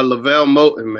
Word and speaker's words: LeVelle Moton: Uh, LeVelle Moton, man.